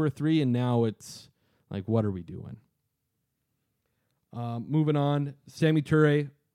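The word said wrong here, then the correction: Samori: I didn't pronounce that correctly. – No, Samari. – Samari Toure. There we go.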